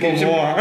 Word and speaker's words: Momoa: Jason Mamamoa le da la cerveza. – Mamamoa. – ¿Cómo se dice? – Momoa. Ah, pues siempre he dicho Mamamoa.